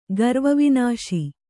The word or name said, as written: ಗರ್ವವಿನಾಶಿ